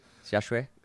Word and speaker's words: XiaXue: Did I say it properly? XiaXue. – It's much better than the first time you were saying it.